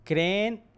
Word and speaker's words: Kremlin: mire, el Kremlin. – El Kremlin, sí, sí, sí.